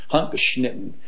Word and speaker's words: Handgeschnitten: It's called Handgeschnitten, that's how you say it in German, Handgeschnitten.